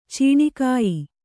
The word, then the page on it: ಚೀಣಿಕಾಯಿ: ciṇī kāyi